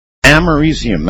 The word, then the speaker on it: americium: Americium, that's it. That's how you pronounce it.